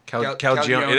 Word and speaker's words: Calagione: Calagione. Calagione. Is that how you pronounce it? – Yeah.